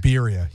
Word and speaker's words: birria: Birria, yeah. – How do you go about pronouncing it in Spanish? In Mexico, it would be said as birria.